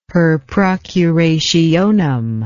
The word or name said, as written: per procurationem